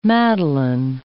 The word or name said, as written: madeleine